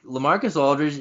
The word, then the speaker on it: LaMarcus Aldridge: Like, no, like LaMarcus Aldridge is. You're right. You said it correctly.